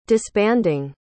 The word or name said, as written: disbanding